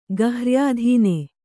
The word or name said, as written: ಗಹ್ರ್ಯಾಧೀನ